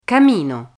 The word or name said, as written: camino